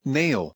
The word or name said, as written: nail